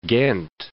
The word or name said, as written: Ghent